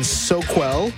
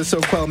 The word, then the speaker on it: Soquel: Also to anonymous friend in Soquel, California. I think I'm saying that right. To $10 a month. Thank you, my friend. – Right. – Holler. Shout out to the Soquel Massive.